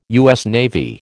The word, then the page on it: USN: American English